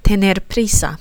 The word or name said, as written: tener prisa